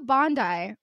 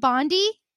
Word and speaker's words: Bondi: Noire as Studio Bondi, with an intense amount of confidence. It's Team Bondi, and it's pronounced Bondi, it's not Bondi, which, to be fair, Bondi, B-O-N-D-A-I, is important, is the name that's on your brain.